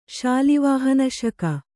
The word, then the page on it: ಶಾಲಿವಾಹನಶಕ: Kannada